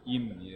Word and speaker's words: 임: 예, 음하고 임이 굉장히 이제 힘들죠. 임, 예, 임.